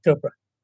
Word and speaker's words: Chopra: Yeah, Deepak Chopra.